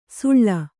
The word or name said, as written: ಸುಳ್ಳ